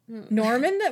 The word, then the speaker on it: Gnorm: Norman? Was it Norman the Gnome? – Norm the Gnome. – Norm the Gnome. – But it's spelled G-N-O-R-M, yeah.